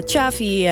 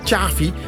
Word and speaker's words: Xavi: Want jij zegt Xavi. – Ik zeg Xavi. – Xavi, dus met T-J eigenlijk. Of is het jij zegt Xavi. Ja, echt Xavi. Zo'n Hollandse. Ja. Hollandser kan niet. – Hollands, hè? Klopt, ja. – Maar het is Xavi.